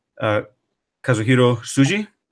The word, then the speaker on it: Kazuhiro Tsuji: Kazuhiro Tsuji? – Yes, that's correct.